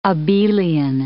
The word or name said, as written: abelian